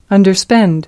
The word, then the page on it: underspend: American English